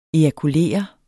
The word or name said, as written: ejakulere